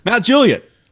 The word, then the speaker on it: Mount Juliet: Mount Juliet, the well-known suburb of Nashville, Tennessee, is phonetically pronounced Mount Juliet, stressing the first syllable. The Juliet is clipped short rather than drawn out like the Shakespearean Juliet, which is a dead giveaway that someone is an out-of-towner.